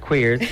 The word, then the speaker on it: Cuirs: My all-time favourite cologne to wear is, believe it or not, pronounced Queers, spelled C-U-I-R-S by Carner Barcelona.